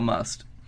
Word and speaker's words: musth: There's, when I worked at the zoo, their bull male elephant, because I know a little bit about must. – Is that pronounced must? – Pretty much, yeah.